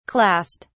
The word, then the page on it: clast: British English